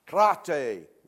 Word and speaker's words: krate: First word, krate. You want to say it? Krate, it almost sounds like karate. Pronounced just as it's spelled.